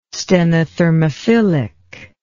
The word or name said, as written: stenothermophilic